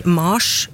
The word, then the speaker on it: Mars: Og i latin så ble navnet til krigsguden Mars uttalt nettopp med lang A. Mars.